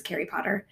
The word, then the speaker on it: Carrie Potter: You may have noticed that my name rhymes with Harry Potter. My name is Carrie Potter.